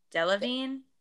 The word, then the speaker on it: Delevingne: Delevingne.